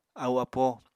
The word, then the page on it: ahu-apo: Apatani